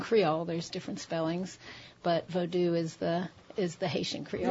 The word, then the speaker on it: Vodou: Vodou, I say. – Vodou. – Which distinguishes it from the touristy Hollywood version, voodoo.